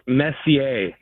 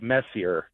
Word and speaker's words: Messier: Yeah, just for note, it's Hall of Famer Mark Messier, not Mark Messier. – Okay, my bad. My bad. Name pronunciation, not a strength on the show. – Not for you. – It's something I've really struggled with. – Just think French.